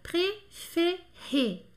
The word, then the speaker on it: préféré: E aqui nós temos três E's e os três têm um som E. Préféré, que significa preferido, né? Préféré, preferido.